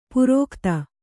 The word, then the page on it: ಪುರೋಖ್ತ: purōkhta